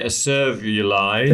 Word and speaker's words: acervuli: I would say acervuli.